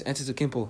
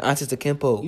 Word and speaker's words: Antetokounmpo: No, you didn't say that right. – He's a walk. I didn't say it right? – No. – How you say it? – It's not tempo. – I said Antetokounmpo. – You said tempo.